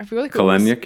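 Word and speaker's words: Kelenic: I think Kalenic, yeah.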